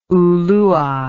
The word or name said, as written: ulua